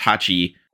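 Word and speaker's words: Hachi (八): 8 in Japanese is pronounced Hachi, which is a homophone for the word bee, also Hachi.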